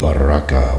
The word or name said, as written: baraka